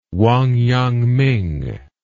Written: wangyangming

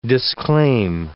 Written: disclaim

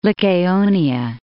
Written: Lycaonia

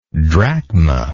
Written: drachmal

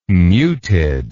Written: muted

